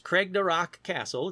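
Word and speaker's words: Craigdarroch Castle: Let's move over to Cragdarach Castle. It sounds like I'm speaking Klingon, but it's pronounced Cragdarach Castle.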